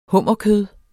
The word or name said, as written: hummerkød